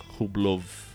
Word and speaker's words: Rublev: Eu falo Rublev porque, assim, todo mundo chama ele de Rublev, que é como escreve, mas na Rússia a pronúncia é Rublov, assim, né?